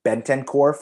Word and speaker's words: Bentancur: They bring in Bentancur from Juventus and Kulusevski, also from Juventus.